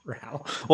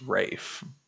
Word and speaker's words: Ralph: Well, it's spelled Ralph, so I can understand why a lot of people say that. But if you ever listen to him in an interview, he says it's Rafe.